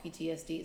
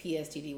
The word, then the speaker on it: PTSD: I always say this wrong. PTSD, is that right? – Mm-hmm. – Sometimes I say PSTD, which sounds like a disease.